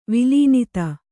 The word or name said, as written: ವಿಲೀನಿತ